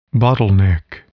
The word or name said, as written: bottleneck